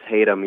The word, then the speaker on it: Hatem: Actually, it's Hatem. You know, it's like Tatum.